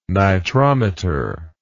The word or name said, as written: nitrometer